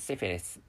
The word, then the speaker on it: syphilis: So syphilis is syphilis. Syphilis is the correct pronunciation. And the spelling is, wait a moment, please. The spelling is SYPHILIS. Please make sure this point.